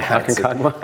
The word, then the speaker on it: Aconcagua: So clearly the journey up Aconcagua, is that how you say it? – Aconcagua. I had to practice that one. – Aconcagua.